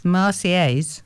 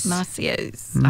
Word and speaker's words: Marseillaise: Did I say it right? The Marseillaise. – Marseillaise. – Yeah. You don't say the L's are of silence. – No, it's a yes sound. – Marseillaise. – I think that's how you say it.